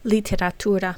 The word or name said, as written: literatura